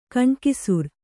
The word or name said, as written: ಗಣಾಕ್ಷರ